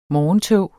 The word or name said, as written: morgentog